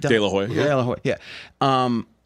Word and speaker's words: De La Hoya: De La Hoya.